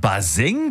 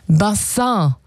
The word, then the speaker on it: Bassin: Wir haben Baseng bisher immer falsch ausgesprochen? Ja, das kleine Ding, wo du als Kind immer reingehuckt bist? – Ja, der kleine Swimmingpool, das kleine Planschbecken. Ja, es kommt eigentlich aus dem Französischen. Bassin. Und bedeutet Becken oder Wasserbecken. – Das kam so, das kam so im 18., 19. Jahrhundert hier rüber nach Deutschland. Wie viele andere Begriffe aus dem Französischen damals. Und die Aussprache, die hat ein bisschen gehapert. Also statt Bassin wurde es irgendwann Baseng.